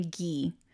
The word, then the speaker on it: Guy: And the man who was convicted for her murder, his name is Guy Paul Morin, but in the videos I saw, they pronounce his name Guy. – Oh, okay. – So I wonder if it's French or something. Because they're in Canada. – Yeah, so I'm going to call him Guy, but it's spelled Guy.